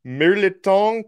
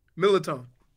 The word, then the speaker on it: mirliton: Mirliton casserole. – What? – Mirliton. – Ah, close. – Don't pronounce the first R. Mirliton.